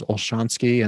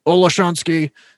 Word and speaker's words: Olshonsky: Olo is my nickname, and actually stems from a pronunciation of my last name, which is Olshonsky. And my lacrosse coach in high school called me Olshonsky.